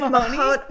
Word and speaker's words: Mahanoy: Is it Mahoney? – It's like Mahanoy. Mahanoy or something. – Right, Mahanoy Area School District, yes.